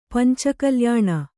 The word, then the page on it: ಪಂಚಕಲ್ಯಾಣ: Kannada